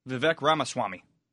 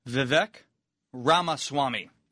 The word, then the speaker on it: Vivek Ramaswamy: No. Vivek Ramaswamy. – What's that? – Vivek Ramaswamy. – Right. This guy was on the show, though. – On our show? – I know I pronounced his name incorrectly.